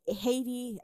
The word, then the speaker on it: Haiti: Also, the H in Haiti, Haiti is silent in both Haitian Creole and French. Haiti, not Haiti.